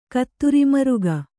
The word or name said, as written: ಕತ್ತುರಿಮರುಗ